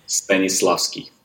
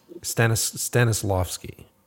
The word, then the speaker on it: Stanislavski: How do you pronounce the name? – Stanislavski. – Stanislavski. – Yeah, right? You nailed it. Yeah, Stanislavski.